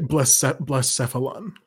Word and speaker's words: Blacephalon: It's like Blast, B-L-A. – Oh, okay. I was looking up B-L-I for some reason. – Yeah, Blacephalon.